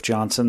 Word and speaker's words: Johnson: My name is Derf Johnson. That's spelled D-E-R-F-J-O-H-N-S-O-N, and we rise in opposition to this piece of legislation.